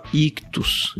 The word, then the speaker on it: Ichthus: Se você quer fazer parte dessa rede, também acessa aí a descrição do programa ou catarse.me ictus Ictus. Ictus se escreve I-C-H-T-H-U-S.